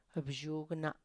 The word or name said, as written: bzhugna